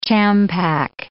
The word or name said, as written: champac